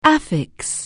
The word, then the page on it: affix: anglais